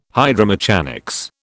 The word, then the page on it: hydromechanics: British English